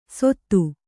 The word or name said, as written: ಸೊತ್ತು